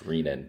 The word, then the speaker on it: renin: Yeah, so a nephrologist might get picky and tell you that it's pronounced renin, but that's just the way they are.